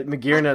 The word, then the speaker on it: Magearna: Uh, Magearna is what it's called now. Yeah. – I don't understand yeah. why it's called Magearna if it's spelled like this. – Oh no, that's not. It's spelled differently. This is the Japanese like transliteration. – Yeah, oh. Magiana is what a Japanese person would probably say if they tried to read Magiana.